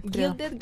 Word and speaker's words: Gilded: Gilden se dice Gilden. – Gilded Glamour. glamour.